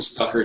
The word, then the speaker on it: Stuecker: It's my first and last name, Jason Stucker. It's spelled S-T-U-E-C-K-E-R.